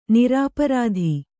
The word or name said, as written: ನಿರಾಪರಾಧಿ